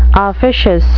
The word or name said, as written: officious